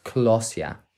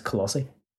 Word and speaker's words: Colossae: Ephesians, Ephesus, Colossians, Colossia. – Colossia? – Is that how you pronounce it? – It's Colossae.